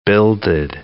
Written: builded

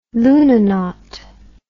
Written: lunanaut

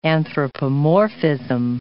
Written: anthropomorphism

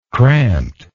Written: cramped

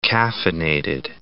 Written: caffeinated

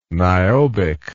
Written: niobic

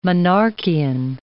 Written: Monarchian

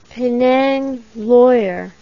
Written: penang lawyer

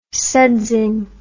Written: sudsing

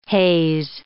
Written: haze

